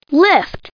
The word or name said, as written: lift